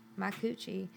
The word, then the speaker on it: Micucci: Micucci.